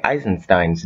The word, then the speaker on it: Eisenstein: It was directed and co-written by Sergei Einstein and produced by Moss Film. – Hey guys, from the future cutting in here. Yes, we are aware we mispronounced Sergei Eisenstein's name right there.